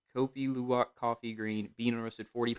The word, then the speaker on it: Kopi Luwak: It's called Kopi Luwak.